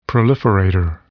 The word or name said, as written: proliferator